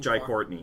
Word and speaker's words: Jai Courtney: Jai Courtney.